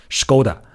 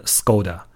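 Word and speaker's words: Škoda: 那么说回斯柯达这个品牌吧，这个品牌如果按照人家正规的读音，应该读成 Skoda，对吧？而不是 Skoda。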